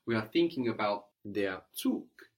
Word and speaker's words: Zug: Here the G on the end of the word is hardened, so it sounds like a K, Zug.